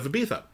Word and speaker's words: Ibiza: But there's one he writes about the island of Ibiza, which you're supposed to pronounce with a TH instead of a Z.